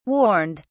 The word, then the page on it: warned: British English